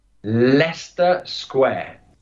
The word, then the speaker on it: Leicester: Okay, and we'll go for the official pronunciation. – Leicester Square.